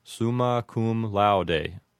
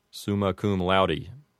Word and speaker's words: summa cum laude: The first one is summa cum laude, and if you wanted to pronounce it with an authentic classical pronunciation, you might say summa cum laude. However, an Americanized pronunciation would render it as summa cum laude.